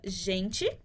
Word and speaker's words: gente: Por exemplo, se eu falar a palavra gente, em espanhol a gente pronuncia gente ou gente.